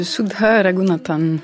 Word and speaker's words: Sudha Raghunathan: Il s'agit de Sudha Raghunathan.